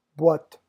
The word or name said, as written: boite